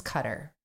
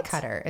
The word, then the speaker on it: Qatar: And so we did like a little poll and we asked you guys, do you think by the way, everyone says it's Qatar. So I'm just going to say Qatar instead of Qatar.